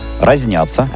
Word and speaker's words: разнятся: Разнятся. – Разнятся. И это очень часто неправильная формулировка, потому что есть единственное верное ударение в этой фразе. Мнения разнятся.